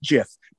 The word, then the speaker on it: GIF: and it's pronounced "jiff."